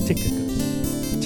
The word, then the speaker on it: Tychicus: And I actually talked about this guy many, many years ago, but the way you pronounce his name is Tychicus.